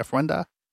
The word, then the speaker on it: ofrenda: and uh, we never actually mentioned what the ofrenda is like that. Is that saying that right? Ofrenda.